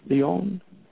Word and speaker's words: Lyon: This is out of France and Lyon. Is that how you say it? Anyway, I think it's Lyon, right? Lyon.